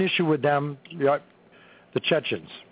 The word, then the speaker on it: Chechens: Doc said that, Seasons? Did I say that correctly? I know I didn't. – Yeah. – Seasons? – Chechens. – Chechens. Chechen rebels.